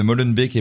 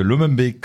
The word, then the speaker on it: Molenbeek: Euh, il y a donc Les Barons, dans lequel je parle bien de Molenbeek et pas de Lomenbeek, comme j'ai mal prononcé avant.